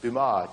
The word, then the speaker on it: Dumas: How do you say it in French? Alexandre Dumas. Yeah, just Dumas. Don't pronounce half the letters and you got it, right?